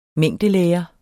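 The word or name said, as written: mængdelære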